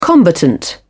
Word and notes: combatant: British English

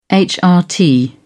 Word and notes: hrt: British English

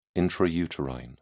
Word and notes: intrauterine: British English

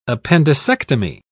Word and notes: appendicectomy: British English

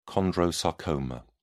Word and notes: chondrosarcoma: British English

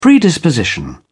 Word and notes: predisposition: British English

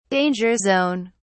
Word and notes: danger zone: British English

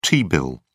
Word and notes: t-bill: British English

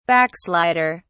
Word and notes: backslider: British English

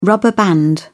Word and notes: rubber band: British English

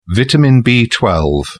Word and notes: vitamin b12: British English

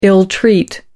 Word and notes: ill-treat: American English

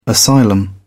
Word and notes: asylum: British English

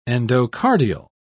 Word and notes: endocardial: British English

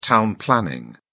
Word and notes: town planning: British English

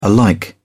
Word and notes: alike: British English

